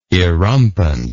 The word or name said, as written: erumpent